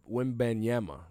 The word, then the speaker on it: Wembanyama: Wembanyama, that's how you say his name. Wembanyama.